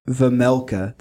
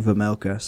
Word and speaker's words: Vejmelka: There's a J in there, so I wasn't sure if it was Vejmelka or Vemelka, but they were saying Vemelka, so I think it's Vemelka.